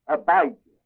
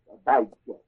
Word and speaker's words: Abijah: Now under Judah, across from Jeroboam, the king reigned in Judah, along with Jeroboam in Israel, was a king named Abijah, A-B-I-G-J-A-H, Abijah. Is it J or G? J-A-H, long I. Abijah, that's good.